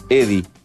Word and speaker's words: Eddy: De Eddie, que se escribe E-D-D-Y. Eddie. Así escrito.